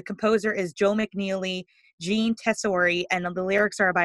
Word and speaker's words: Tesori: Tessori, T-E-S-O-R-I.